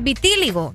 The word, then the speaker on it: vitíligo: Vitíligo. ¿Lo dije bien? – Vitíligo. – Es que ahí tiene acento en la I, vitíligo.